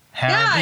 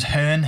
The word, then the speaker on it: Hearne: Hearn? Yeah, okay. – Because my last name's Hearn, H-E-A-R-N-E, and people spell it wrong all the time.